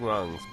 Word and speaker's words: Wongs: Whiting Wongs. – Like, and it's spelled just how it sounds, like whiting, like the color white, Wongs, W-O-N-G-S.